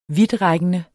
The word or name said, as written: vidtrækkende